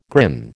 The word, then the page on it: grin: American English